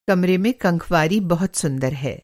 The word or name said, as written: कंखवारी